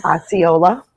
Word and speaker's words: Osceola: Osceola.